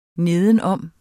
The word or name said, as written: nedenom